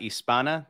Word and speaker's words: Hispana: La Comunidad Hispana. That's it. We're good. – Give me that again. – La Comunidad Hispana. You don't pronounce the H. And don't make it Hispania.